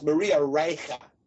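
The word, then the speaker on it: Reiche: and I believe there was a woman named Maria Ritchie. Well, I know there was a woman named Maria Ritchie, and I think the pronunciation, she was German, so it's Maria Reicha.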